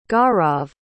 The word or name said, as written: gaurav